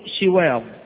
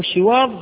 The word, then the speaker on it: شواظ: كلمة شواظ تنطق بضم الشين وكسرها لغتان، وقرئ بهما في القراءات المتواترة، يعني تقول شواظ وتقول شواظ. فشواظ بالضم أو شواظ بالكسر بمعنى اللهب الذي لا دخان معه، اللهب الذي لا دخان معه.